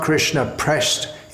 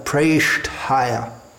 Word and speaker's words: Preshthaya: When we see an E written, it's pronounced as A, something like hey, may. So it's not Krishna pressed, it's not Krishna pressed higher, pushing Krishna up. Preshed higher, Krishna preshed higher. There may be a tendency to pronounce the T almost as a D, which we find in among some native English speakers. But it should be pronounced very clearly and distinctly, differently from a D, Preshthaya.